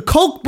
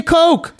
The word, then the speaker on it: Koch: Spell it different. It's Koch. – Yeah, that shit is the Koch brothers. – K-O-C-H. – Yeah. – But they've been saying Coke to throw us off so we couldn't Google it.